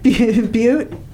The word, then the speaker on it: Butte: Butte. – Is that by Booty, Montana? – Yeah, I'm thinking Booty and Booty. – It's a tomato thing. – Oh. You're saying it wrong, Robin.